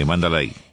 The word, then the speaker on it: Mandalay: El 19 de noviembre en el Hotel y Casino Mandalay, ¿se dice así, querido? ¿Mandalay Bay? – Sí, Mandalay. – Mandalay, Mandalay Bay de Las Vegas, Nevada.